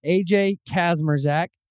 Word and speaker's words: AJ Kazmierczak: I've got AJ Kazmierczak. Did I say that right? – Perfect, man.